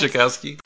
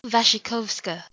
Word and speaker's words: Wasikowska: Wasikowska. – Wasikowska.